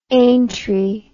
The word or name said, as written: aintree